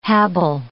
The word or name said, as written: habile